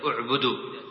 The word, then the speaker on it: اعبدوا: كذلك قوله تعالى يخادعون، يخطف أبصارهم، اعبدوا، تقطع همزة بالضمة عند الابتداء، تقول اعبدوا، وهكذا وقودها، بفتح الواو، ولا تقرأ بضم الواو، ولا يقال وقودها.